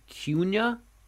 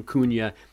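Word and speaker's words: Kuna: I believe it's Cunha. – Cunha. – Probably still getting it wrong. It's not Cunha, like it's spelled.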